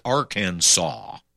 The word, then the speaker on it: Arkansas: Since the letter S at the end of the French words is usually silent, we pronounce Billy Clinton's home state as Arkansas.